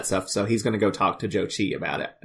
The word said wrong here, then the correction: Choji: Well, you got it backwards. It's Choji.